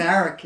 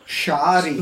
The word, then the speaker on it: Saric: S-A-R-I-C. – S-A-R-I-C. – Correct. – It's Croatian. – The Croatian pronunciation is Shadić, though. – Okay, so go slow. – Shadić. – No, go back to English spelling. – Sarek.